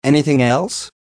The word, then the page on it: anything else: American English